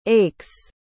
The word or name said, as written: aches